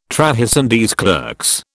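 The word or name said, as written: trahison des clercs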